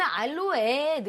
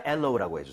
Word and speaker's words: aloe: 이게 파닉스대로 근데 읽으면 알로에 느낌이 나긴 해요. – 알로에. 네. 하지만 엘로우라고 해주세요.